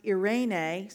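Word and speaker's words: Irene (εἰρήνη): When we take a look at the Greek, it's pronounced Irene. Say Irene. Irene.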